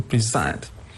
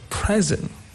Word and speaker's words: present: The same goes for to present. It's a verb, so the stress is on the second syllable, to present. And now, when you change the stress from the second syllable to the first syllable, it becomes a noun, like a present.